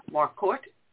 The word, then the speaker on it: Marquardt: I know I'm going to mispronounce her last name, so please forgive me. Kathleen Marquardt? – Yes, Marquardt. Right. – Oh, I just pronounced it correctly.